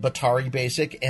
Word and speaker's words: Batari: but just in the last week, I picked up Batari Basic, which is how you how you uh, pronounce it, Batari Basic, and the Visual BB.